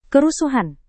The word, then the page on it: kerusuhan: indonesian